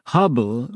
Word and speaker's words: Hubble: Hubble rhymes with trouble.